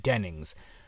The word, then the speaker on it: Dennings: And by the way, I know for the past couple of weeks I kept on saying Kat Jennings, and I'm really, really sorry, it's Kat Dennings. So I, like, she listens to this podcast, I'm sure she doesn't. Miss Dennings, I apologize for butchering your name. It is Kat Dennings.